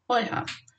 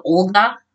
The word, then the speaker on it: Olha: In old Church Slavonic, Oyha is written in the exact same way in modern Ukrainian as it was back then, and pronounced Oyha. In Russian, it's spelled the same, but pronounced with that stupid G sound, Olga. That's because Russian doesn't have that soft H sound.